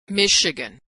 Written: Michigan

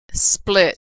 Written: split

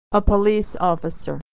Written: officer

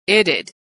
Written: it'd

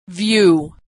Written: view

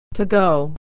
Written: to go

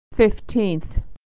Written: fifteenth

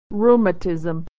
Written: rheumatism

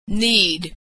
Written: need

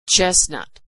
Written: chestnut

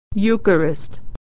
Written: eucharist

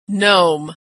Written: nome

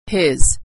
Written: his

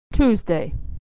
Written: Tuesday

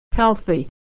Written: healthy

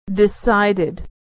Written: decided

